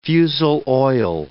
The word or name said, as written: fusel oil